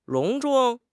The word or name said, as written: 隆重